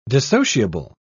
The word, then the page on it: dissociable: British English